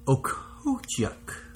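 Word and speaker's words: Okhotiuk: So, Okotiuk. Okay, it's O-K-H-O-T-I-U-K. Nikita Okotiuk.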